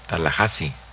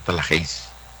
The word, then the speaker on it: Tallahassee: Tallahassee, Florida, se pronuncia Tallahassee, no Tallahassee.